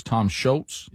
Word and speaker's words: Tom Scholz: All right, so the pioneer guitar player for Boston was Tom Scholz.